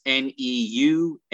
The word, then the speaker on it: Neumann: The key here, Sky, is my last name is spelled weirdly. It's Neumann. /Robert Newman. – And it is pronounced Newman, right? – It is.